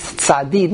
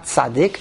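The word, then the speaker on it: Tzadi (צדי): Now we know that the real way to pronounce the letter Tzadi is Tzadi, not Tzadik.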